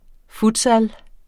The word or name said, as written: futsal